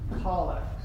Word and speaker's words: collect: Now, it looks like the word collect is pronounced collect.